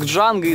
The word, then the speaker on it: Джанго: Там снимается Франк Нера, он подходит в одной из сцен к Джанго и спрашивает, типа, «Как тебя зовут?» Он говорит, «Джанго». Он такой смотрит, «Джанго?» Как это читать? Он такой, «Д не читается, понял?» И Франк Нера такой, «Да».